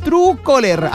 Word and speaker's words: Truecaller: Truecaller, así mismo, ¿no? Se escribe True Caller.